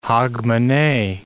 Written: Hogmanay

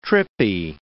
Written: trippy

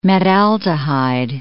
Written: metaldehyde

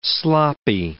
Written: sloppy

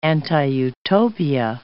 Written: anti-utopia

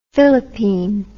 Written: filipine

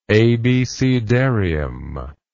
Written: abecedarium

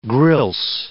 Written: grilse

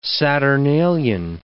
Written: saturnalian

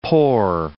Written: pore

